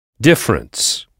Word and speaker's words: difference: Difference. – Oui, c'est ça, difference. Là encore, comme pour different, on insiste à l'oral sur la première syllabe, difference.